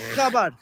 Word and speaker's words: Chabad: Nah, we gotta first say it right. Chabad.